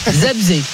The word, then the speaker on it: Zabrze: Gournik Zabrze, c'est pas mal non? – Zabrze, c'est quasi parfait.